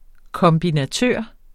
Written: kombinatør

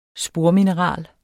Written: spormineral

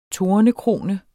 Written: tornekrone

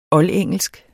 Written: oldengelsk

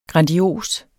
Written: grandios

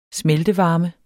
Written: smeltevarme

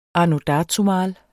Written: anno

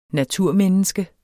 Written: naturmenneske

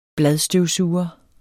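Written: bladstøvsuger

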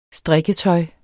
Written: strikketøj

